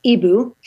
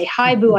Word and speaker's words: hibou: So I like the name, I like the word for owl in French, which is Ebu, but they spell it H-I-B-O-U, but we didn't want people to say hi-bu.